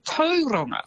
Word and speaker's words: Tauranga: And Tauranga as well as actually Tauranga.